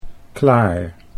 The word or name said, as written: Claar